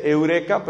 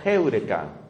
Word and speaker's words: heureka: La gente dice eureka, pero me enseñaron que la pronunciación correcta era heureka.